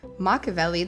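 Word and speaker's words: Makaveli: And it was, most interesting, released under his new stage name, not Tupac, but Machiavelli, which is Machiavelli, the other one.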